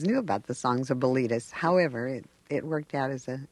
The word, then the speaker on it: Bilitis: You know, some people, when they hear the name, think it sounds like a disease, Bilitis. – Well, that's why we pronounced it Bilitis.